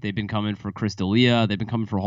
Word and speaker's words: D'Elia: D'Elia? – Chris D'Elia. – D'Elia.